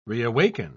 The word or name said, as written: reawaken